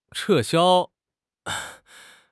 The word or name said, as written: 撤销